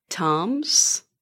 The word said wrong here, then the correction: Thames: You know, English is confusing. There are silent letters in it, and you know, yes, Thames. – Thames. – It should almost. How would you spell Thames? – T-E-M-M-S.